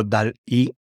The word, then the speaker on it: DALL-E: Y digo DALL-E porque suena como Dalí, pero se escribe DALL-E.